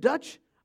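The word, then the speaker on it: Duché: After this, Mr. Dutch, I know it doesn't look like Dutch, but that's how it is pronounced.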